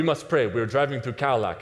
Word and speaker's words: Kaolack: But as we're driving away from Dakar, we're going through this city named Kaolack, not Cadillac. In English, it would be spelled like K-A-O-L-A-K, so Kaolack.